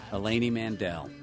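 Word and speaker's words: Eleni Mandell: Eleni Mandel.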